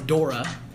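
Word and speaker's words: Daura: So, my last name is Dora, like the Explorer.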